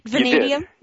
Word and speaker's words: Vanadium: Did I say it right? Vanadium? – You did. Yes, you did. – Yay! – You got it right.